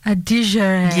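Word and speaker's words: Adigéry: I'd probably give a little bit more of that to that soft G. – Adigare.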